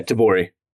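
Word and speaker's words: tebori: That's what is like tabori, T-E-B-O-R-I. I thought I'd spell it just in case I'm not saying it correctly. – Yeah, tabori.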